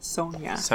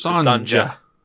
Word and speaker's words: Sonja: Sonja. One of the two. There's a J. I don't think you say the J. I think it's like a Y. But either way. – It's Sonja.